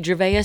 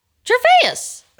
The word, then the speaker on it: Gervais: And pronounce his last name again, please? – Ricky Gervais. – Okay. – What did I say? – It's Ricky Gervais. – Gervais. – Okay. – No, it's not. It's Gervais.